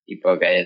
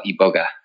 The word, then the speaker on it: iboga: So, you know, iboga is kind of the more, that's how they say it in Africa as well, iboga.